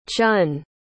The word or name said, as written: chun